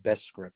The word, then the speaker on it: Bescript: Gary Bescript.